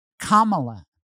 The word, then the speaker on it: Kamala: Her name is Kamala. And if you can't say the word comma, then what's wrong with you? You put a comma in your sentence, say comma, and then say la.